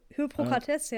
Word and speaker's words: Hippokrates: Hippokrates, äh, ja.